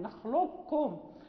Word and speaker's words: نخلقكم: وكذلك نخلقكم روي فيها اختلاف، ولكن المقروء به هو الإدغام الكامل، نخلقكم ننطق بالكاف مشددة ولا يبقى أثر للقاف، ولكن روي فيها الإدغام الناقص كذلك، يعني روي فيها بالنسبة لهذا الباب.